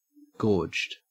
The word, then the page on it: gorged: Australian English